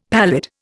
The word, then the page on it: pallid: Indian